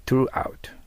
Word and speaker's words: throughout: throughout、ベロ先を噛みますね。